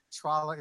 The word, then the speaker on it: Tralla: And in fact, when I was looking at the script last night, every single time Tralla is mentioned in the dialogue, I put in there for the actor to get it right, I put rhymes with Paula.